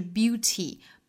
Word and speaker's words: beauty: 美音来说，刚才我们讲到了一个 warn 这个词，美音读 warn，还有一个词就是 beauty 美音会读成 beauty 会把中间这个字母 t 的发音把它弹一下舌发音， beauty 很像是发成了一个 d 的发音，但是我们要知道这个 d 是非常生动活泼的一个弹舌音， beauty 而不是这个硬邦邦的 beauty 然后就不对了。